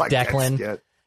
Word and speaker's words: Declan: But people used to butcher it and have it too sharp, and, you know, D-Clan, the Clan, Dacian, everyone couldn't read it right. Now it's Declan. Now it's Declan, and they get it right.